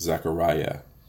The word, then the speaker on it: Zechariah: But even something as Zechariah, there's Yah in that name. It's spelled with a I-A-H, but it's Yah when you really pronounce it. Zechariah.